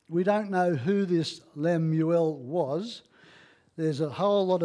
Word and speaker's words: Lemuel: It's in two parts, and so it's it's the words of King Lemuel is how it's pronounced, which is a, uh, is meaning devotion to God.